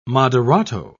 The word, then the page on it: moderato: British English